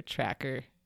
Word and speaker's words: Trakr: T-R-A-K-R. It's easier when you can see your tracker.